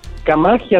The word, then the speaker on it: Camaji: Oye, ¿cómo se dice tu apellido? ¿Camaji? – Camaji, así como suena.